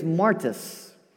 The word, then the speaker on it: martis: This word witness in the Greek is martis. A witness was a martis, which might sound familiar. It sounds like the word martyr. That's where we get our word martyr from.